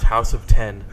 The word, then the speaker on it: House of X: It's pronounced House of 10.